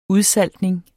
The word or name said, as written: udsaltning